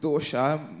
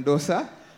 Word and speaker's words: dosa: I should pronounce it dosha. Married to a Malayalam, Malayali speaking wife, so better get it right. It's dosha rather than dosa.